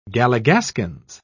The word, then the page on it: galligaskins: British English